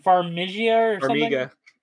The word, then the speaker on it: Farmiga: I think they're, how do you pronounce her last name? Farmiga. Okay, so Tessa Farmiga.